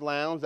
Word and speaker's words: Lowndes: It is actually pronounced Lowndes. That's not just my South Georgia accent.